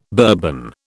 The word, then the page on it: Bourbon: British English